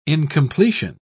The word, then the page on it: incompletion: British English